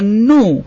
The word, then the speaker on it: GNU: and the answer was new or new. And it's pronounced just like the word new or new and spelled G-N-U.